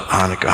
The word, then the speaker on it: Hanukkah: So Hanukkah, it's pronounced the same no matter how you spell it. Hanukkah, Hanukkah, Hanukkah.